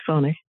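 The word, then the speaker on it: Fani: It's an interesting sort of contrasting approach of legal styles and philosophies because District Attorney Fawny Willis, I believe it's how it's pronounced, right? – Yes, Fawny. – Right. Okay. I'd heard it Fanny, and when I said that earlier, you corrected me because I was like, oh, I don't know, but no, apparently the name is from Swahili, you said? – Yes, Swahili.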